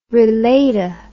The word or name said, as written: relata